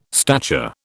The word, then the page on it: stature: British English